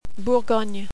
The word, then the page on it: Bourgogne: French